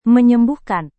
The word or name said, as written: menyembuhkan